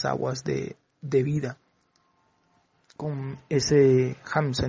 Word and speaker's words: Hamsa: Entonces el maestro enseña en esa conferencia de que el Hamsa debe pronunciarse Ham Mental Inti, Ham más largo que el Sa. Así dice el maestro, que con esa técnica, así es como se debe pronunciar Ham más largo y Sa un poco más corto.